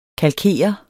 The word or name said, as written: kalkere